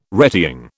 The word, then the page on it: retying: British English